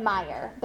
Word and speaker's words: Meijer: And when I lived in Michigan, I also shopped at Fred Meyer, but that one is spelled M-E-I-J-E-R.